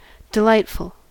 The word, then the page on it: delightful: American English